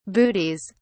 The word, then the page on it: bootees: British English